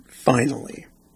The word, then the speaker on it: Finale: Okay, the song is spelled Finale. It's the final song on State of Euphoria. But they distinctly pronounce it Finally.